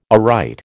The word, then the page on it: aright: American English